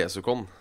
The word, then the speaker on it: Desucon: Eh, er det, kan du, er det uttales sånn? Hvordan ville du sagt det? De sier jo stort sett Dess. Det er jo veldig sjelden Du kan av og til høre UN, men det er jo stort sett DES i Japan. – Descon. Men, men hva er rett uttale da for i Norge? Vi kaller det Desukon, sånn eh, ja.